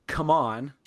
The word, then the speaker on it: CMON: Before this, like just before recording here, maybe it was last weekend, CMON had Come On, is how you're supposed to pronounce it now, had a virtual expo.